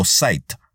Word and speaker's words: site: Para ello hace falta escribir en la barra de búsqueda la palabra site con S o site como se pronuncia en inglés, seguida de dos puntos y el nombre de un sitio web junto a la palabra clave que estás buscando.